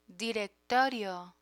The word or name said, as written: directorio